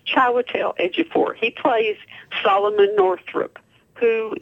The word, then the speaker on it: Ejiofor: Well, no, when he gets the Oscar, and his name is Chiwetel Ejiofor.